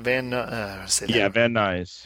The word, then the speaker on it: Van Nuys: And that's Van, how do you say that again? – Van Nuys. Yeah. – Van Nuys. – Van Nuys, California.